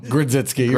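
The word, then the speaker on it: Grodziskie: Grudzowski. Did I just say that wrong? – Grudziski.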